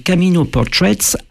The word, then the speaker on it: Camino Portraits: Alors, Camino Portraits. Alors, à ma prononciation, les auditeurs ont peut-être dû être surpris, mais il s'agit effectivement du mot portrait, mais en anglais, et donc Camino en espagnol.